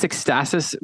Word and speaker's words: Ekstasis: Sean, I don't know if you know about these guys, but it's Ecstasis Magazine, and that's spelled Ekstasis.